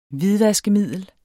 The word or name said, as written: hvidvaskemiddel